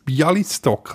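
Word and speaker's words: Białystok: Irtisch hat es aufgeschrieben, Bablodar gegen Bialis, Bialistok. Das habe ich richtig ausgesprochen aus Polen. – Ja, also mein Polnisch ist auch eher äh, äh, ja, übersichtlich, aber ich glaube, du hast das richtig ausgesprochen.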